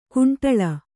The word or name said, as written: ಕಂಡಾಳು